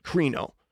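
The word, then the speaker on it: krino (κρίνω): But I listened to it on a Greek Bible website, and that's how they pronounced it, krino.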